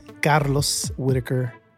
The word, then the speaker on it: Carlos Whittaker: I am Carlos Whittaker, by the way, that's how you say my name, I'm a hope dealer.